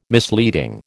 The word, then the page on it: misleading: American English